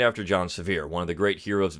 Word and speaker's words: Sevier: I was actually reading a book about John Sevier, which if you pronounce his name properly, was Xavier, John Xavier. But we know him today as John Sevier.